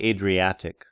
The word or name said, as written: Adriatic